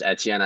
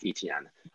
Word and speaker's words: Etienne: Yeah, so my running back one is Travis Etienne, whom I learned his name is pronounced Etienne. I thought it was Etienne at first. You know, I'm a French speaker, but it is, in fact, Etienne.